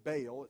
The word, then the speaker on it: Baal: One of the things that Ahab did is there was this false god named Baal. It's spelled B-A-A-L.